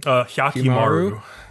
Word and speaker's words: Hyakkimaru: Hyakkimaru. Yeah, Hyakkimaru, basically how he ended up where he is now.